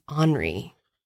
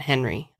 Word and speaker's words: Henri: I think his name is Henri. It's like pronounced Henri Paul. It's not Henry.